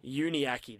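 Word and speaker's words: Davies-Uniake: Luke Davies, now, can you help me pronounce this? Uniaki. – Uniaki, that's it.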